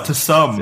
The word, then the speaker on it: Tsum Tsum: No, it's fucking Tsum Tsum. – Can you spell it for us? – T-S-U-M, T-S-U-M. – Tsum Tsum. – Oh, to some. It's to some, to some.